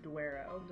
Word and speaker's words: Douro: I probably need to pronounce the U more. Douro.